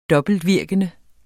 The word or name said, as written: dobbeltvirkende